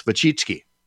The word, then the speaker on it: Wojcicki: What people don't know, and I'll put it up on screen for people watching on Facebook, her last name is spelled W-O-J-C-I-C-K-I. And that's Wachitsky, I think.